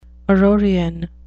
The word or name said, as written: aurorean